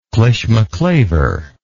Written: clishmaclaver

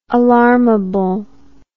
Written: alarmable

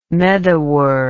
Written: medawar

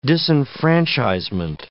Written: disenfranchisement

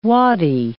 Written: waddy

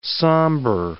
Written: sambur

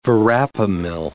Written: verapamil

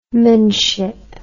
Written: manship

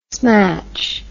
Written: smatch

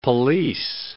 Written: pelisse

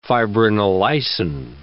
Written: fibrinolysin

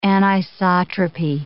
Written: anisotropy

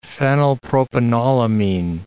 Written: phenylpropanolamine

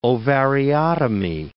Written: ovariotomy

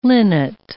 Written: linnet

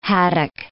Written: haddock

